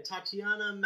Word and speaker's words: Tatiana: Yeah, Tatiana Maslany.